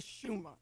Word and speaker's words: Shema: This morning, I'm going to talk to you about God, starting with the Shema, and it's spelled S-H-E-M-A.